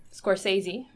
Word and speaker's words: Scorsese: And Scorsese, am I saying it correctly? – Yes.